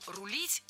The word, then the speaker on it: рулит: Кроме того, ударение в нашем слове тоже стоит всегда за корнем, как и в начальной форме. «Рулить», «рулит», «рулим», «рулиш». Поэтому корректный вариант – «как ее рулиш».